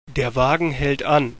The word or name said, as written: anhalten